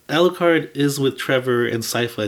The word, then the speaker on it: Sypha: It's, it's It's pronounced Sypha in the yeah. cartoon.